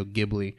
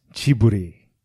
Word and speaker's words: Ghibli: It wasn't airing on Tuesday, the Studio Ghibli movie. – So it is pronounced Chiburi. – Chibli. – Japanese, it's Chiburi. So it's Ghibli. It's Ghibli.